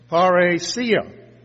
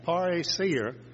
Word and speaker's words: parrhesia: And it's parhesia, P-A-R-R-H-E-S-I-A, parhesia, if I'm pronouncing it according to the pronunciation guide in Strong.